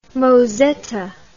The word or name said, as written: mozetta